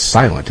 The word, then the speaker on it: SLNT: We pronounce it as silent, and that makes sense, doesn't it?